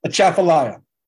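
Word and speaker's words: Atchafalaya: Achafalaya.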